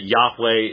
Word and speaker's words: Yahweh: I'm well aware, for example, that Yahweh is the proper pronunciation, tetragrammaton.